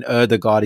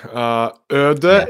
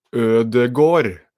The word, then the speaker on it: Ødegaard: No, you're saying it wrong. Ødegaard is correct. Okay. But the final part is Gård. Ødegaard. – Okay. Interesting. – So instead of having a sound, you're having sort of an O sound.